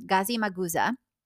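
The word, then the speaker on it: Gazimağusa: They are two historically significant sites located in North Cyprus near the city of Famagusta, Gazi Magusa, is how it's said, I believe, in Turkish.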